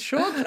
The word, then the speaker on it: chod: Vi starter med banchod. Chod. Ja, det er chod!